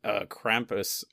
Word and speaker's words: Krampus: And then Krampus, you know, he comes, like St. Nicholas gives Krampus.